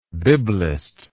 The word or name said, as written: biblism